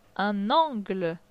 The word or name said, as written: Un angle